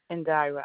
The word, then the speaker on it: Indira: Indira, I-N-D-I-R-A. – Okay, that's I-N-D-I-R-A. – I-N-D is in David, I-R-A, yes, ma'am.